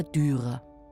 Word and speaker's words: Dürer: Oder eben fränkisch ausgesprochen, der Dürer.